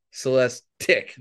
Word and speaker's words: Celestiq: Not Celestiq, Celestiq. They're very, rhymes with Lyric, their other new EV. – Okay. – Yeah. So I kept going, so it's Tick, not Teak.